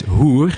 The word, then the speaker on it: hoer: And let's say you have H-E-E-R. It's pronounced here. But when you say H-O-E-R, it's hoer.